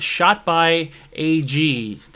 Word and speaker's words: Shot by AG: Today I have with me Adam from Shot by AG. Did I say that right? – You did.